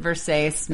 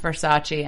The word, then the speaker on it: Versace: Let's talk about I just love the fact that she buys the dress and she calls it Versace, not knowing it's pronounced Versace,